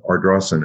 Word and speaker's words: Ardrossan: Um, I know that you were born, as we discussed in Ardrossan, and Ayrshire.